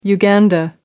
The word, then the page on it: uganda: American English